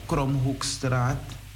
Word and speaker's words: Kromhoekstraat: En het is eh, Kromhoekstraat, eh, nummer 136. Zeg ik het goed? Ik moet het nu toch onthouden hebben. Ja, het is goed.